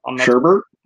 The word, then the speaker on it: sherbet: Yes, I don't think there's an R in sherbet generally, but you say it. You use the R. Sherbet.